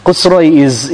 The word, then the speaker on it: Khosroes: It's written here as Khosroes. I don't know how to... Khosroes is the actual pronunciation, but you know, in English, how they pronounce it, Allahu Alam.